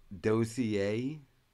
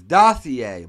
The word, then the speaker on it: dossier: It's pronounced dossier. It's dossier, motherfucker.